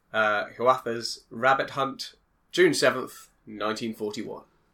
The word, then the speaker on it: Hiawatha's: Hiawatha's? – Yeah.